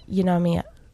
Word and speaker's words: pneumonia: Jaundice, conjunctivitis, or pneumonia? How do you say it? – Pneumonia. – Pneumonia. – Pneumonia. – Ignore the P.